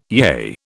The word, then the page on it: yea: British English